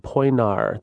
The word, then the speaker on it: Poenari: It's called Poenari. The I at the end is silent. – So Poenari, that's Dracula's real castle.